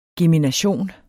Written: gemination

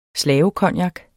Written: slavecognac

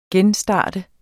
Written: genstart

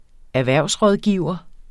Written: erhvervsrådgiver

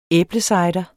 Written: æblecider

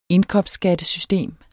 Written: indkomstskattesystem

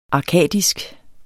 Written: arkadisk

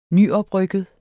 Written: nyoprykket

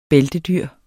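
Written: bæltedyr

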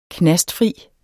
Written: knastfri

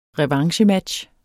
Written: revanchematch

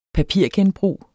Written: papirgenbrug

